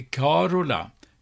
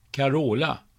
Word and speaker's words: Carola: Det äldre uttalet i Sverige är Carola med betoning på första stavelsen i likhet med det latinska mansnamnet Carolus. Senare har namnet med betoning på andra stavelsen, Carola, blivit det vanliga.